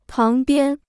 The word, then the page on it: 旁边: páng biān